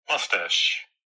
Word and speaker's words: moustache: So the British way of saying it, they don't have a British version, but the American way is this way. – Moustache. – I say moustache. Isn't that interesting, though, that when I get to the word, I'm confused about how to say it. It must be because there's different variation. – Moustache. – Aha. – Moustache. – You see how there are two different pronunciations of this word? – Wow.